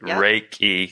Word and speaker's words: Reiki: Reiki, because I've said it several different ways.